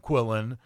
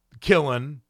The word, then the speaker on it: qilin: But anyway, he takes this Quillen, Q-I-L-I-N, that's how I'm going to pronounce it, Quillen or Quillen.